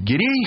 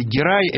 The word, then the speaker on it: Гирей: Гирей или Герай – это, как я понимаю, немножко разное произношение одной и той же фамилии в разных языках. Но я привык говорить Гирей, я уж буду так говорить.